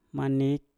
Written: manik

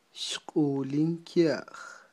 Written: xq'ulin kya'j

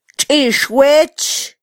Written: txixwech

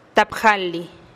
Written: tap jalih

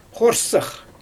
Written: xoorsax

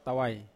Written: tawai

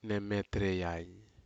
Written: nemetrei ai

